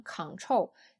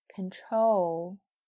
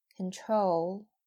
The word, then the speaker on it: control: 那么变成了 control 的一部分以后，它就不是 con 了，所以很多同学读错也会读成 control，这也是错误的，应该是。 control，重音在 trol 上，前面是 can，right？control。好，那接下来呢，再给大家读几个词，对比试一下。 con、 control、 add、 advice、 red、 reduce、 mat。